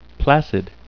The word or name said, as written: placid